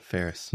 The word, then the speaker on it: Faires: Yeah, Ferris.